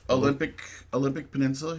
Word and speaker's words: Olympic Peninsula: Olympia Peninsula? – Olympic Peninsula.